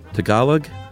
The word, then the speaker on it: Tagalog: Once again, it's Tagalog. – Tagalog. – Tagalog? – Tagalog. I think. – Well, this is G-A-H, not G-U-H.